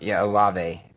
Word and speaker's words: Olave: and one wide receiver you didn't mention is Chris Olave. – Olave. – There you go. Sorry, I totally butchered that last name.